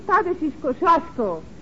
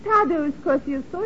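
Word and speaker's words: Tadeusz Kościuszko: In Poland, we say his name Tadashis Kosciuszko. But in America, Tadeusz Kosciuszko is fine.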